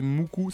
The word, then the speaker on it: MUCC: et pendant cette vague donc de Visual Kei, moi, il y avait mon groupe préféré, c'était Muku, ça s'écrit M-U-C-C.